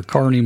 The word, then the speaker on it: Kearney: Frank James died on the old family farm near Kearney, Missouri.